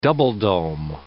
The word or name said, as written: double-dome